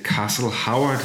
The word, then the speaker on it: Castle Howard: Vi kan så, Til Castle Howard hedder.